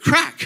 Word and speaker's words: craic: And it's this word, crack. And I was like, I will not be having crack tonight. No, thank you. But it's spelled C-R-A-I-C.